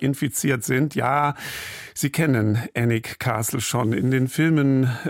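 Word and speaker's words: Alnwick: Da muss ich Ihnen gleich noch sagen, das spricht man ganz anders aus, als man es schreibt. Also wenn Sie es suchen sollten, wird geschrieben A-L-N und dann Wick. Annick, Annick Castle.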